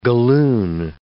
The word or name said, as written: galloon